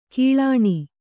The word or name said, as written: ಕಿಳ್ಳಾಣಿ